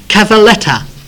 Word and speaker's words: cavalletta: It's also interesting that the Italians call the locusts cavalletta. The actual pronunciation by the Italians, or the word they used to describe the locust, is cavalletta, and it literally means a little horse.